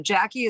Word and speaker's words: Jacqui: So Jackie is J-A-C-Q-U-I. And then yeah. Pearl, it's pronounced Pearl, but it's Pearl. P-I-R-L, like girl with a P.